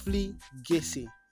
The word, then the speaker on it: profligacy: one huge show of pro profligacy. Yes, I got it. I got it right this time around. I've been pronouncing that before I started recording. Profligacy.